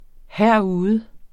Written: herude

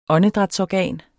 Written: åndedrætsorgan

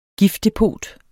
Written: giftdepot

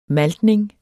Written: maltning

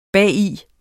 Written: bagi

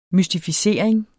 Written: mystificering

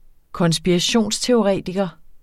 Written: konspirationsteoretiker

